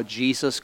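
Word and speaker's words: Jesús: I was sitting across from a guy whose name is spelled J-E-S-U-S. Now, he's Spanish, so it's pronounced Jesus, but his name is Jesus.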